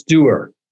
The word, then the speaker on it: DUER: Tell me a little bit about DUER, and I think I'm pronouncing that correct. It's Doer. So doer. Yeah, it's really for all you do in a day.